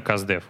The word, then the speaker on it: CustDev: А, от английского это Customer Development, сокращенно Каздев. Вот поэтому так оно и произносится.